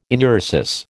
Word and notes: enuresis: American English

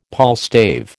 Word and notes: palstave: American English

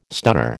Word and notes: stunner: American English

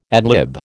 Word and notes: ad lib: American English

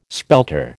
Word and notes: spelter: American English

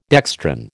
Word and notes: dextran: American English

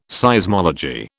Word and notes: Seismology: British English

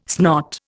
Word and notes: snot: Indian

